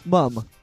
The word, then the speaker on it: mum: How do you say it in British? – Mum. – Happy birthday, mum. – Happy with a U, not an O.